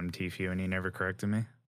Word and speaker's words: Tfue: Have you ever worked out with Tfue? – Never. – Am I pronouncing this right? – I think so. I say Tfue. Some people say Tfue. I say few.